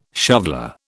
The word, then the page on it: shoveller: British English